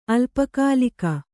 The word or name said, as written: ಅಲ್ಪಕಾಲಿಕ